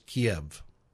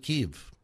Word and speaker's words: Kyiv: I grew up thinking the capital of Ukraine is Kyiv. That's the Russian pronunciation of the capital. Ukrainians pronounce it Kyiv. It's spelled differently in the transliteration. So the Russian transliteration is that K-I-E-V, it's K-Y-I-V in the Ukrainian. So I'll call it Kyiv.